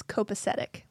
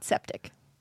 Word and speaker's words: copacetic: We did, but you're both pronouncing it wrong. It's copacetic, not septic.